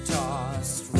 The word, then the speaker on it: tossed: But, and then next, like a coin that won't get tossed, T-O-S-S-E-D, if you're not looking at the lyric video, T-O-S-S-E-D, that S-E-D, it sounds like T, tossed.